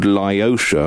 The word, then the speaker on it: Liotia: So you make music in a band called Lyosha. Am I saying that right? – Yep. Excellent.